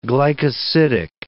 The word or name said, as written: glycosidic